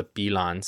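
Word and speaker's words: Pillan: Wekufes entered the Mapu world as a consequence of the mythical battle amongst the Bilan spirits. It's P-I-L-L-A-N, and I think it's pronounced Bilan. I don't think it's the same double L-Y sound as in Spanish.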